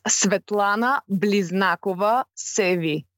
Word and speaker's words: Svetlana Bliznakova Sevi: Okay, it's Svetlana Bliznakova Sevi.